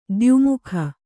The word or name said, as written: ದ್ಯುಮುಖ